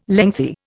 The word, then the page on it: lengthy: Indian